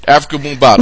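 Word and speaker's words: Afrika Bambaataa: Africa Bambaataa.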